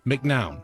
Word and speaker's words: McNown: You know, I always say because it's M-C-N-O-W-N. McNown. – Yes. So that is how I pronounce it and have pronounced it for my whole life.